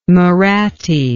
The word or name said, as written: Mahratti